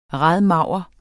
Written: radmager

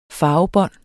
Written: farvebånd

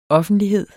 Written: OFFENTLIGHEDERNES

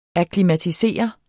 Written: akklimatisere

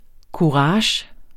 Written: KURAGES

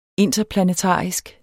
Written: interplanetarisk

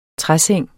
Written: træseng

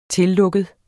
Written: tillukket

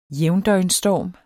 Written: jævndøgnsstorm

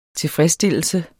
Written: tilfredsstillelse

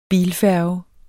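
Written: bilfærge

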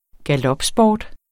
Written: galopsport

